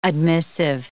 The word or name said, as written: admissive